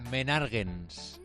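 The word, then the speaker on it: Menàrguens: ¿cómo se pronuncia? – Menarguens. – Ah, casi. Menarguens. – Has dicho con un acento diferente. Menarguens en Lleida.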